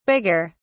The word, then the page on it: bigger: British English